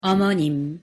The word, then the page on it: 어머님: Korean